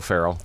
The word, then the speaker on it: Ferrell: Close. It's Bill Ferrell.